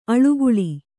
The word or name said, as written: ಆಳ್ಗೊಳ್